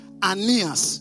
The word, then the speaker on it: Aeneas: And his name was, actually we pronounce it like the way we pronounce Ananias, but it is Aeneas. Do you get it? It is Aeneas. So, or Aeneas. But that is how we pronounce it.